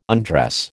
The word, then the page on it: undress: American English